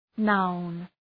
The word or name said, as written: noun